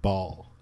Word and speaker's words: Baal: I noticed that they're pronouncing it Ball. – Ball, yeah. – Yeah. – Oh, yeah, that's right, yeah. – Yeah. – Oh, we figured that one out, huh? – Yeah. – B-A-A-L, right? Ball.